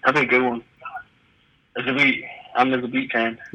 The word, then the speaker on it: Zabit: Did I say his name, Zabit? – Yeah, Zabit Magomed Sharipov.